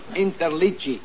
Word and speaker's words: Interliggi: My name is Joseph Interligi. I-N-T-E-R-L-I-G-G-I.